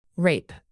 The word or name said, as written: rape